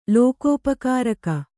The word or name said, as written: ಲೋಕೋಪಕಾರಕ